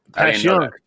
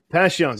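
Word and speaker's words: Passyunk: Pashyunk. Pashyunk. That's how they say it down there. Pashyunk.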